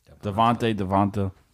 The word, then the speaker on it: Devonta: Devonta. – Oh, he's pronouncing it the way it's spelled.